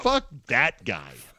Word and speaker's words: Fuck That Guy: Week one of Fuck That Guy. – Okay. – ESPN's... – No. You're saying it wrong. Oh. Fuck that guy. The punch has to be on the that.